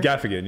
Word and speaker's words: Gaffigan: Like, sure, you could be the Jim Galfinini. Is that how you pronounce it? – Is that the it? – Guy with the puppets? – No. – God, I hate that guy. – Jim Gaffigan? Tim Fader? – Gaffigan, yeah. Where he's just like, he's super PG.